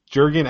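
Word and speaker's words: Jürgen: This is not my dislike, but Max McCarty, Dax McCarthy, Jergen, as in, I probably said it wrong, but Jergen as in, like, the hand lotion.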